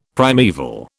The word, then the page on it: primaeval: British English